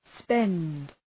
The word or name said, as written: spend